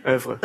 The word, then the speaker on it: oeuvre: Ook binnen iemands eh, oeuvre kan nooit dat woord uitspreken. – Oeuvre, oeuvre. – Oeuvre. – Oeuvre, ja.